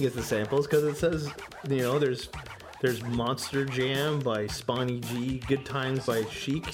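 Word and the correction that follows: Spoonie Gee: It's spelled S-P-O-N-N-I-E. – Yeah, Spoony. – That's Spoony? Okay.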